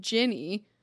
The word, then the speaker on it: Jenny: um, so it's I say my name Jenny, which most people are like, oh, so it's G I N N Y, and I'm like, no, it's J E N N Y, and they're like, that's Jenny, and I'm like, exactly.